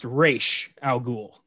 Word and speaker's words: Ra's al Ghul: So it's pronounced Ra's al Ghul. that's how it's supposed to be said.